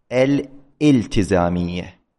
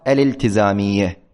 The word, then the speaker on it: الالتزامية: من الأخطاء الشائعة في هذا اللفظ أن يقال الالتزامية خطأ شائع كثيرا الاستصحاب الجملة اسمية، هذا خطأ، يقال الالتزامية، الاستصحاب، الاستقتال، الاسمية. هذه الهمزة هنا همزة وصل لا تلفظ وليست همزة قطع، كثيرا ما يتلفظ بها على خلاف الصحة.